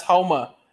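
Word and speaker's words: Houma: Huma, Louisiana. Huma? – H-O-U. It's pronounced Homa. H-O-U.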